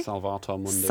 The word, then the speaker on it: Salvator Mundi: Did I pronounce that correctly? – Salvator Mundi. – Salvator Mundi. And sorry, I was corrected yesterday.